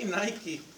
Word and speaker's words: Nike: Esta palabra vencer, sí, vencer en el griego, es la palabra, sí, Nike. Así la pronunciaron y así la pronunciamos acá.